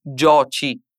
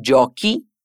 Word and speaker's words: giochi: Non possiamo dire tu gioci, noi giociamo. E quindi mettiamo l'H e continuiamo con la pronuncia gutturale. Tu giochi, noi giochiamo.